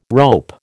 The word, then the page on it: rope: American English